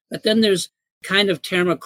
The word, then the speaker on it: Terma: How do you say that? – T-E-R-M-A, Terma.